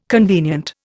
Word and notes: convenient: Indian

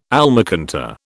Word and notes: almacantar: British English

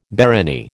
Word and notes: barony: American English

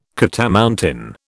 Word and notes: catamountain: British English

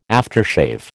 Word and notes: aftershave: American English

